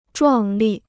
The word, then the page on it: 壮丽: zhuàng lì